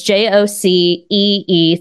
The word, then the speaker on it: Jocee: Um, my username is No Way Jocee, and it's spelled mm. a little hmm. weird. – It's J O C E E.